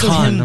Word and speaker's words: Khan: He's like Genghis Kong. – Yeah. – Or it's Khan, I'm sorry. Khan. I keep saying that wrong.